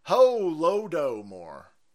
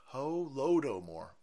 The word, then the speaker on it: Holodomor: It's pronounced Holodomor. Holodomor.